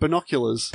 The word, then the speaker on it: binoculars: Binoculars? Is that right? Is that how you say it? – Binoculars. – Binoculars, that's better.